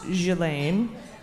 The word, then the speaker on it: Ghislaine: We get a lot of corrections about the pronunciation of Ghislaine Maxwell. We are well aware it's pronounced Ghislaine. We call her Ghislaine.